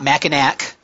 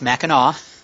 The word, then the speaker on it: Mackinac: It is not Mackinac. Uh, it's Mackinac. uh, it's the uh, French influence of northern Michigan.